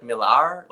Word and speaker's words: Millar: He goes, it's actually like Millar, like with a little R's roll.